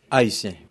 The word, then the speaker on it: Haitien: Okay, my name is Joseph Pierre Cadet, nickname Haitien. – That's the French pronunciation of Haitian.